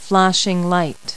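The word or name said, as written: flashlight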